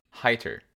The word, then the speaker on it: Heiter: and I've settled on Heiter, is the priest. – Okay. – Because E I is I, and the Japanese, at least Romanji, name of him, for him is Haita.